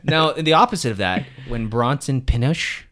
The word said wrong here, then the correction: Pinchot: I pronounce it Pinchot. Oh, Pinchot. – Pinchot? I say Pinochet, but I know that's not correct.